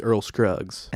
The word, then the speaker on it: Earl Scruggs: His name's Earl Scruggs.